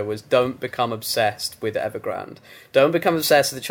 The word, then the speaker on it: Evergrande: The word of the year that I've chosen is Evergrande, or as some people will pronounce it when they're coming to the story for the first time, Evergrande. not Evergrande, Evergrande.